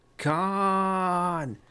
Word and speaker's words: Cannes: It's just that you don't pronounce the S in Cannes. It's like Star Trek 2. Cannes.